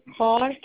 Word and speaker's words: Kaur: Is Gurvinder Kaur? – Will you spell it for me? – Gurvinder. – Okay. And your last name? – Kaur, K-A-U-R.